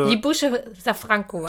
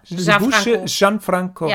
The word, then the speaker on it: Libuše Šafránková: Also Libuše Šafránková. – Šafránková. – Ja, J mit J. – J? – Genau. – Libuše Šafránková. – Ja, genau.